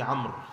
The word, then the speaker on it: عمرو: قال الإمام الحافظ أبو زكريا يحيى بن شرف النووي رحمه الله تعالى عن أبي عمرو، وقيل أبي عمرة سفيان بن عن أبي عمرو هكذا وليس أبي عمرو، الواو هذه في عمرو لا تلفظ، عن أبي عمرو،